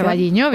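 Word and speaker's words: O Carballiño: Vamos a hablar de la fiesta del pulpo, que se celebra en Ocarvalliño. No lo sé pronunciar. – Ocarvalliño, bien. – Ocarvalliño.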